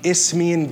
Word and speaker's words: Isthmian: And they know he's talking about the Isthmian Games. Don't ask me how to spell it, but it's Isthmian is like the phonetic, okay? Isthmian Games is what they do, what they did in Greece.